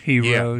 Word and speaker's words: gyros: Blanket-wise, gyros. That's more Greek. But in the Mediterranean, it's gyros. And that's even for one. It still ends with an S. – Mm-hmm. – You're eating a gyros.